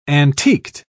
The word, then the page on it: antiqued: British English